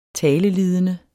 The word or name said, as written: talelidende